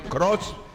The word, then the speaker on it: Croce: No, pues yo creo, Croce. Pues ese se escribe C-R-O-C-E, Jim Croce.